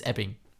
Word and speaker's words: Ebbing: Well, you did just pronounce it Edding, Missouri, so, but until then you were doing great. It's Ebbing.